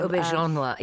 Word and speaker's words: Auberjonois: Yeah, René Auberjonois, I think is how you say his name. – Auberjonois, yeah. Auberjonois, I think. Something like that.